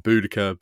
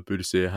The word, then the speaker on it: Boudica: And Boudica, Boudicea, however people want to pronounce the name, she's one of those characters that kind of looms large in the British historical imagination.